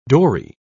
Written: dory

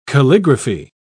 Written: calligraphy

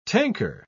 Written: tanker

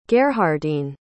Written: gerhardine

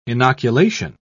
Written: inoculation

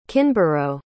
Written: kinborough

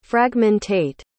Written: fragmentate

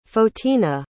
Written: photina

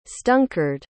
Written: stunkard

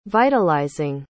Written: vitalizing

